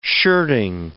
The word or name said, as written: shirting